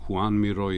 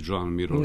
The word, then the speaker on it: Joan Miró: Mislim da bi on rekao posle ovog snimka na kraju krajeva koji smo čuli i tako dalje, tu on govori da ima dvostruko poreklo, da ima i špansko i katalonsko. Uh-huh. Dakle, mogao bi on da se složi i sa izgovorom Juan Miró i Joan Miró.